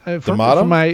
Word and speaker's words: D'Amato: It's not, because there's this, and actually, this is rare for D'Amato films too. Am I saying that right? – D'Amato, yeah.